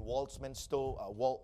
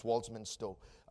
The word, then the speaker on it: Walthamstow: He pastors the church in Walthamstow. Don't know if I'm saying it right. I think it's Walthamstow, in London, UK.